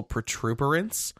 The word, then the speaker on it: protuberance: I think it's protuberance, not protuberance. I think I say it wrong because of The Office. – Protuberance? – Protuberance. – You know, you want to add an R, but there's actually no R in the whole word.